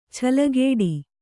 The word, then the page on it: ಛಲಗೇಡಿ: Kannada